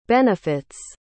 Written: benefits

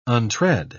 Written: untread

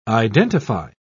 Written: identify

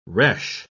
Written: resh

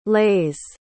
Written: leis

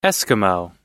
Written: eskimo

